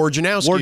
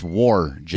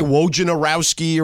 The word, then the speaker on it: Wojnarowski: Adrian Wojnarowski is how you say it. Yes. – When I used to work over at Fox, they made it very clear it's Wojnarowski.